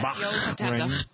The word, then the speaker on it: Bahrain: Then Bahrain. Is that how you pronounce it? – Yeah. Bahrain.